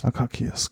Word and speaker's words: Akakis: Dann steht halt hier Akasis, würde ich sagen, wird es ausgesprochen, oder? Sparkling 2017. Dementsprechend Akakis, genau. 11,5% ist halt auch ein Weingut, von denen wir heute zwei Weine haben.